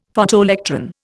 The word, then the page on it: Photoelectron: Indian